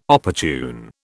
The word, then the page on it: opportune: British English